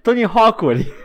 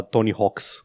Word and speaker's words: Tony Hawk: n-am avut problema asta, nu l-am pronunțat niciodată greșit, pentru că știam că e un apostrof și n-am avut niciun dubiu Nici, niciodată. asupra da. pronunției. – oh, nici eu, dar era foarte multe lume care îl pronunța Tony Hawks.